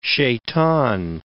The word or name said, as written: shaitan